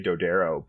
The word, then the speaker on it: Dodaro: Both are actually correct. We say Dodaro, but it was there are Dodaros in Italy that we are related to.